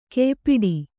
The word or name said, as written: ಕಯಿಪಾಡಿ